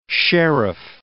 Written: sheriff

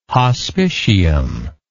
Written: hospitium

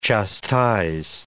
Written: chastise

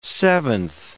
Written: seventh

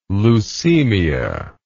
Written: leucemia

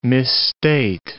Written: misstate

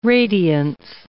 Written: radiance

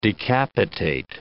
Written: decapitate

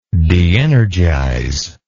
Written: deenergization

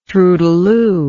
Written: troudeloup

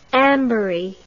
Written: anbury

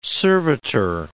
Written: servitor

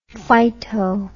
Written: phyto

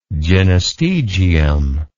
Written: gynostegium

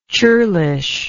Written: churlishness

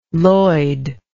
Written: Lloyd

